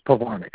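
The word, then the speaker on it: Pivarnik: Last name is Pavarnik.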